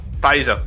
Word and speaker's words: Pfizer: The company Pfizer, I don't know if I pronounced that right. – Pfizer, whatever the name is.